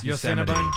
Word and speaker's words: Yosemite: You didn't say it right, but we know what you meant. Yeah. Yeah. – Yosemite.